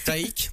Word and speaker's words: Taïk: Taïk, donc. – Taïk comme Mike, mais avec un T.